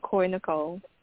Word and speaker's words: Koi Nicole: Yes, you said Coy Nicole.